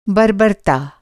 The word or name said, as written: बर्बरता